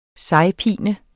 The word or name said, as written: sejpine